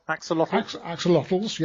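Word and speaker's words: axolotl: This is the extinction plight of the Axolotls. I think I've pronounced that right. It looks like it to me. – Yeah, pretty close. – Axolotls, yeah.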